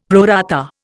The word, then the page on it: pro rata: Indian